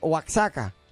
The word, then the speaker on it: Oaxaca: Ah, pero se llama así ese lugar, Oaxaca.